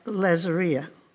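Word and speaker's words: Lazaria (العيزرية): The Palestinian name for Bethany is pronounced Lazaria.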